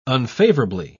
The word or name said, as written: unfavorably